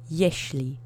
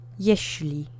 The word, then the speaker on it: jeśli: There's no special phoneme in here, and the accent is on the first syllable. Repeat after me. Jeśli. Jeśli.